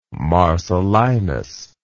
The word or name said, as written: marcellinus